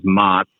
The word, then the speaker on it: Mats: Uh, my name is Mott. It's spelled M A T S, but uh, think of like Mott's applesauce, yeah.